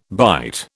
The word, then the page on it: bite: British English